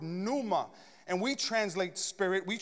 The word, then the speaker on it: pneuma: And in the Greek, which is the language which our New Testament is written, it's pneuma. Everybody shout pneuma. See, some of you were trying to pronounce it. You were like, pneuma? The P is silent, all right?